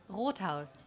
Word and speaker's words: Rothaus: Rothaus? – Oh, we have a German pronunciation. – Okay, say it one more time. Say it in the mic. – Rothaus.